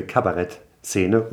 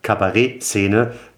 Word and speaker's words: Kabarett: Aber in Wien gab es sehr früh eine sehr, sehr lebendige Kabarettszene oder Kabarettszene. Da wurde es ja französisch ausgesprochen, wie uns Nette ja auch berichtet hat.